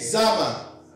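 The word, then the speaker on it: Zamar: Oh, say it like you mean it. Zama. That's Z-A-M-A-R.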